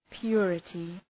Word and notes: purity: ‘pjʋrətı

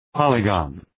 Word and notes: polygon: ‘pɒlı,gɒn

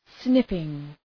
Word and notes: snipping: ‘snıpıŋ

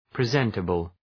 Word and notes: presentable: prı’zentəbəl

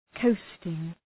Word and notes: coasting: 'kəʋstıŋ

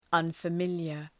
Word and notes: unfamiliar: ,ʌnfə’mıljər

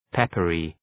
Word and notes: peppery: ‘pepərı

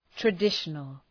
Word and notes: traditional: trə’dıʃənəl

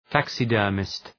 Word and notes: taxidermist: ‘tæksıdɜ:rmıst

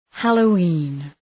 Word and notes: Halloween: ,hæləʋ’i:n

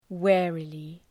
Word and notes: warily: ‘wærəlı

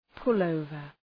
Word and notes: pullover: {pʋl'əʋvər}

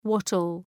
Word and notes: wattle: ‘wɒtəl

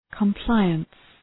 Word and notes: compliance: kəm’plaıəns

